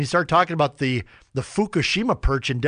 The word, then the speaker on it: Fukushima: It's a U, long U, Fukushima.